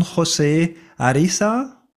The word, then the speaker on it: José Ariza: Also du hast dann in irgendeiner Form von Don José Arisa, habe ich das richtig ausgesprochen? – Ja, sehr gut.